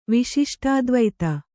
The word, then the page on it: ವಿಶಿಷ್ಟಾದ್ವೈತಿ: viśiṣṭādvaiti